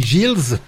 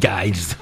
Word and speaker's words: Giles: Ouais, ça s'écrit G-I-L-E-S. Et il y en a des qui disent qu'on dit Giles. Et d'autres qui disent Giles. Ouais, voilà. Bon, moi, je dis Giles.